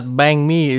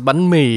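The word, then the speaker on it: bánh mì: Well, I reckon it's like it's not banh mi, it's banh mi.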